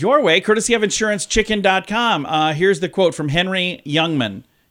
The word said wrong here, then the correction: Henny Youngman: Henny Youngman is how you say it.